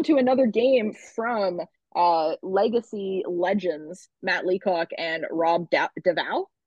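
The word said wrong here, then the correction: Daviau: Davio. – I'm going to say his name. Davio, thank you.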